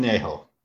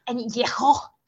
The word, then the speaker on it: añejo: Do you mind saying it correctly? – Hang on, let me read the label here. I need some, where is it? – Añejo. – Añejo. – Thank you. That's all I'm saying. There. Let's pronounce things correctly on the show. We love to segue into things. I didn't mean to kill the guy. – No, it's okay. That was actually the correct dialect.